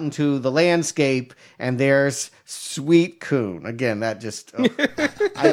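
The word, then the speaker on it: Suicune: It's not sweet coon? – It's Suicoon? I mean, they say Suicoon. – Suicoon is what it is, but the way that it's spelled looks very much like suicide.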